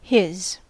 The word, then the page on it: his: American English